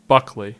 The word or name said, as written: Buckley